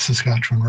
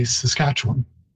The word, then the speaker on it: Saskatchewan: And but it is interesting that Ken already mentioned earlier that people inside the province say Saskatchewan, and outside you hear that Saskatchewan. It's actually because we know how to pronounce it. We've heard it all along since it was borrowed, and have kept closer to the Cree pronunciation than outside, where people see the spelling W-A-N and so mispronounce it as Juan.